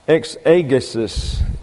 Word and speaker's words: exegesis: Exegesis is the way that is pronounced. Exegesis. And when you bring the E's over into the English, it sounds like a short E in our pronunciation. But an eta is long in the Greek and receives a pronunciation similar to the long A in English. So it's exegesis, and it means the interpreter.